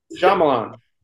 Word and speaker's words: Shyamalan: If you saw my head go to the right, it was from your pronunciation of Shyamalan. – Yeah, how do you say it? – M. Night. I want to say it like that from now on. – How am I supposed to say it? – Shyamalan. – It's Shyamalan. Shyamalan? It's not Shyamalan? Yeah. That's the way I talk.